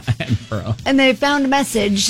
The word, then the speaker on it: Edinburgh: Now, I need to stop you real quick because you're pronouncing it like you're in Indiana. It's Edinburgh.